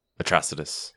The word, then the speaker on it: Atrocitus: Atrocitus. – Atrocitus. – That's how I would pronounce it. I think I've seen, yeah, that's what I've seen.